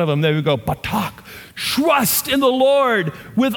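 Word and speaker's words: batak (בטח): Now that phrase trust in the Lord if you understand the Hebrew the way the Hebrew language is shaped, this is an emotional word called batak. Batak, and I just butchered that with my southern accent, but that's how you say it.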